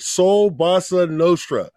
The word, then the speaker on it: Soul Bossa Nostra: Soul Bossa Nostra.